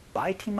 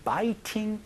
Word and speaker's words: biting: I have a habit of biting my nails. biting 이라는 그 T 소리는요, 몸소리 가운데서 biting my nails, biting 이런 식으로 굴려서도 발음하는 경우가 많으니까 이것도 같이 알아두시면 좋겠습니다.